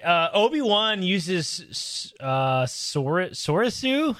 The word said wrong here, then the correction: Soresu: How do you say it? Sorasu. Soresu, yes, which is for number three.